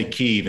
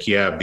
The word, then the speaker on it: Kyiv: So, I mean, we all say Kyiv instead of Kiev these days because Kiev's the Russian pronunciation and Kyiv's the Ukrainian pronunciation.